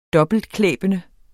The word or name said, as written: dobbeltklæbende